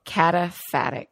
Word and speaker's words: cataphatic: Cataphatic is how you say that word.